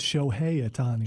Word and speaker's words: Shohei Ohtani: The American League Rookie of the Year, Shohei Ohtani. – I think it's Shohei Ohtani. – Shohei. So I wasn't far. I wasn't far off. Shohei Ohtani was named the American League Rookie of the Year, and rightfully so.